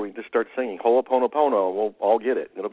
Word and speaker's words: Ho'oponopono: You're darn close. It's Ho'oponopono. – Ho'oponopono. Shame on me. – You're just pronouncing every syllable, and it's a Hawaiian name.